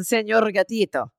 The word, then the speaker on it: Señor Gatito: The Señor Gatito. You have to roll your R because he's Latin.